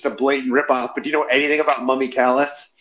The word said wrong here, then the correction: Mummy Calls: Oh, it's Mummy Calls. I'm saying it wrong.